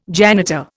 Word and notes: janitor: Indian

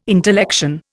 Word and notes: intellection: Indian

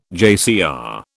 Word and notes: JCR: British English

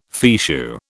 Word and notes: fichu: British English

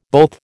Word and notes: both: American English

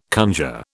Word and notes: conjure: British English

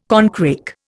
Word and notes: corncrake: Indian

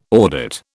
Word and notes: audit: British English

